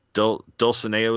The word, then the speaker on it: Dulcinea: One, while it can be sad that Don Quixote never met Dulcinea, is how we were pronouncing it? – Yeah.